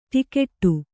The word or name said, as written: ಟಿಕೆಟ್ಟು